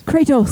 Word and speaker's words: Kratos: Kratos.